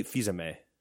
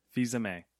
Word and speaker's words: Fils-Aimé: How do you say his last name? – Reggie Fils-Aimé. – Fils-Aimé. – I don't think there's an L.